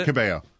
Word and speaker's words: Cabello: Because I know there was a good success with Kiss and Camila Cabello, if that's how you say her name. – Yeah. Camila Cabello.